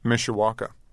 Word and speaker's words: Mishawaka: Mishawaka. – In Indiana. Was that a small town or a suburb? – Yeah, it's 40,000 people. – Mm-hmm. and describe your neighborhood for us. – Mishawaka, there was like a big Unoro plant that was in the 50s that closed down, I think, in the 70s.